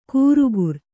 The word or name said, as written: ಕೂರುಗುರ್